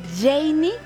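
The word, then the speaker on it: Jeiny: No te olvides seguirme en redes sociales, arroba Radio Disney MX, arroba Janey Capistrán.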